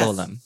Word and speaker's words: golem: Am I pronouncing that right? Is it golem or yes. golem? I think I think you're right.